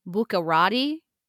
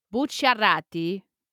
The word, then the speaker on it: Bucciarati: So in English, you can pronounce that last name as Bucarati. – Okay. – But you would sound like a huge manja cake if you said it that way. So okay. – Bucciarati is how I would say it.